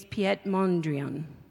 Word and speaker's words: Mondrian: His name's Piet Mondrian.